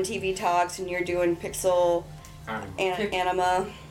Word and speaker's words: PixelAnima: Say it properly. – Pixel anima. – Anima. – Anima. – Yes. It's just too related to the other word when you say it. So you have to say anima as in anima.